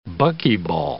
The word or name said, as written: buckyball